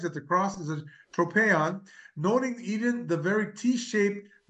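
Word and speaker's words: tropeion (τρόπαιον): The Greek word that St. John Chrysostom uses for war memorial is tropeion. I think that's how it's pronounced, tropeion.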